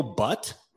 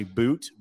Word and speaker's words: But: Daniil Butt, yeah. Arizona, 12th overall. Now, his last name is pronounced, it's actually Boot, but it's B-U-T.